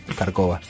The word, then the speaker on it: Kharkova: Ahí se le cambia el acento en el barrio, no sé por qué. Kharkova.